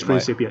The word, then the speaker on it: Principia: How do you pronounce it? – Principia. It is Principia. Pronounced Principia. – I tell you, not bad. Not bad. – Very good. Very good. Most people say think it's Principia, right? – That's what it looks like.